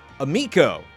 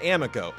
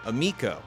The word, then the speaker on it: Amico: Amico rhymes with amigo, not amico. Amico.